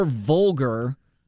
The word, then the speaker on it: vulgar: Yeah, for those who were upset that my conversation with Bob yesterday wasn't more Vogel. More Vogel? Frank Vogel? – Good Lord, man. We are mispronouncing things all over the place. – Yes, we are. – More vulgar, excuse me, more vulgar yesterday.